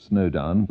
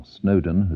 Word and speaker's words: Snowdown: In fact, the first of the four main collieries in Kent to close, Chislett, to the north of Snowdown, lasted barely 50 years. The three others, Snowdown itself, or Snowdon as it's often pronounced, Tilmanstone and Bettshanger, lasted longer.